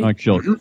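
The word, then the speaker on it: Mike Shildt: You say his name, Mike Schilt, right? – Mike Schilt, yeah.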